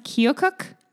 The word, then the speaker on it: Keokuk: He built a home in, and if you live in this place in Iowa, forgive me, I think it's pronounced Keokuk. He ran for office.